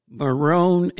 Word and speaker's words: Morón: So he takes us to a base called Morón Air Base. It was spelled Moron, but they pronounced it Morón.